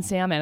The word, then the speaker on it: salmon: My date pronounced the L in salmon, and I said out loud, well, you're not the one.